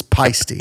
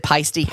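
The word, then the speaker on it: Paiste: We've been corrected by a listener on our pronunciation of it's Pisty. – Is it Pisty? Okay. – So they said, make sure next time this comes up, it's Pisty cymbals.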